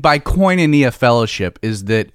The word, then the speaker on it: koinonia: it's koinonia.